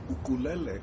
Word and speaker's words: ukulele: And ukulele is what everybody on the mainland calls the ukulele. Because the pronunciation is ukulele, because uku means bug and lele means jump.